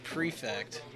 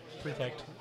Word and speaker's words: Prefect: They're called the Prefect. But it looks like it's spelled perfect. And I asked him about that. He's like, no, it's Prefect. And I was just like, but it looks like it's spelled perfect. – Yeah, Prefect. – Yeah, but it's pronounced different.